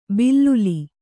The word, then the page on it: ಬಿಲ್ಲುಲಿ: Kannada